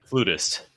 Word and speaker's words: flautist: Flautist. That's how you say it. – Flutist.